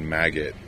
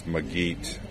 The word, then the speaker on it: Maged: When he first said his name, I thought he said Maggot, which kind of threw me off. But it's Magit, and it's spelled M-A-G-E-D.